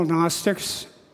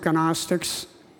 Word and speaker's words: Gnostics: those we call Gnostics, or some people pronounce it Gnostics, and the Docetists.